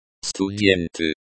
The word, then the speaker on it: Студенты: Студенты. Students. Студенты. The stress is on the first syllable.